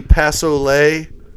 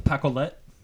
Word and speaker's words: Pacolet: Is that the Passolet mm-hmm. – Outfitters? – River Outfitters. Yep. Hates it when we pronounce it like that. – Mm-hmm. – Pacolet. – Yep. Pacolet. There we go.